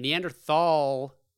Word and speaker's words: Neanderthal: This is funny because he talks a lot about Neanderthals, and Neanderthal, it has a th in it, but people say it's pronounced Neanderthal. – Yeah. – And I'm like, get the fuck out. If you want to be pronounced Neanderthal, take the fucking th out of there.